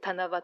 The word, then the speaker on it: 七夕: あ、なるほどなるほど、はい、であの七夕はあのご存知の通り、この織姫彦星伝説ありますけれども、はい、織をするということの七夕から、この七に夕という字をこう当てて七夕と読むんですね。